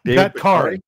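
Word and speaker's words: Bakhtiari: Bugatti? Something like that? – Oh, Bakari. – Bakari.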